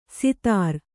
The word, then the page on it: ಸಿತಾರ: sitāra